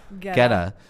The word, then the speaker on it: Guetta: Guetta.